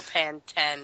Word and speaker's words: Pantene: She needs some Pantene, or Pantene as it's pronounced in the UK.